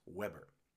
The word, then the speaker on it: Weber: No, I know it looks like it's spelled like Weber, but it is pronounced Weber.